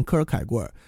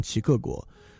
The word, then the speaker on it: 齐克果: 呃，齐克果的原因是因为科尔凯郭尔，一会儿我们会看到那名字的拼写啊，那个拼写，如果你觉得用英文的音标来念呢，呃，感觉应该像念科尔凯郭尔。但其实用他本身的语言来念，他是个丹麦的哲学家来念呢，其实发音像齐克果，所以其实我们也知道译一个人的名字啊，应该用他的这个当地语言的发音来译，所以其实齐克果是更正确的译法。